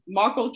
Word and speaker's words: Markle: Is it Meghan Merkel? Am I saying that right? So Meghan called, Markle, I think. – Markle.